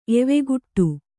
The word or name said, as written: ಎವೆಗುಟ್ಟು